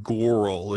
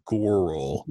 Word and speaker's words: Gouroull: It's something like Gourl. It's spelled G-O-U-R-O-U-L-L. Gourl. – Gourl.